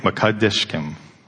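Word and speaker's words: Mekaddishkem: Yahweh Makadishkim, the Lord who sanctifies you. Do you want to say it with me? Yep. Yahweh Ma-Kadish-Kem. Yahweh Ma-Kadish-Kem. Not Kadish-Kem, Ma-Kadish-Kem.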